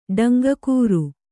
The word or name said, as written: ಡಂಗಕೂರು